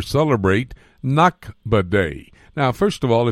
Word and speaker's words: Nakba: One is Naksa, N-A-K-S-A, and the other one, which you just pronounced correctly, is Nakba, N-A-K-B-A.